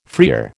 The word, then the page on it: freer: American English